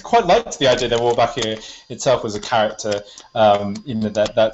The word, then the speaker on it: Wolbachia: Which, if I practiced, I could pronounce, but it's Wolbachia. – Wolbachia, yeah.